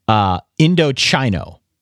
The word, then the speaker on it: Indochino: It's spelled I-N-D-O-C-H-I-N-O. When I first read this many moons ago, I pronounced it Indochino on the very first time that I read this. And then from that point forward, they sent over a handy little pronunciation guide. So now it's Indochino. They have chi, C-H-E-E in all caps here so that I don't screw it up.